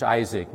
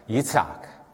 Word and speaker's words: Yitzhak: That's how we pronounce it in English, Isaac. But in Hebrew, it's something like Yitzhak.